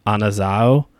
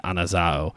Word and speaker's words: Anazao: I do believe it's actually pronounced Anazao, because it's a omega at the end, Anazao.